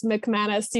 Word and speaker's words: McManus: It's Lily with one L, and then it's McManus. You just got to sound it out like mk.